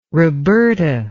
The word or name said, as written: roberta